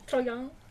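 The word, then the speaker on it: Troyan: comunque scopro che Cédric Nicolas Troyon, Troyon, ho deciso che si pronuncia così perché è eh.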